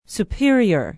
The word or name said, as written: superior